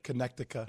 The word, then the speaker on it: Connecticut: Do you say Connecticut? Like, how do you pronounce the name of that state? – It's Connecticut. – Okay, good. – Connecticut.